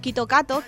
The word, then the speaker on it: Kit Kat: El Kit Kat se pronuncia, o sea, Kit Kat se pronuncia como Kito Kato, que es muy parecido a Kito Katsu, que significa ganaremos con seguridad, que para ellos es como dar buena suerte.